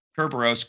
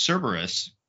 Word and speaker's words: Cerberus: Like they name, but then I put in Kerberos, Greek god, and found it is pronounced Cerberus in English, and it's a pretty common Greek god we've seen in a lot of things.